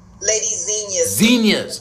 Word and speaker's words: Zinnia's: Lady Zinnia's.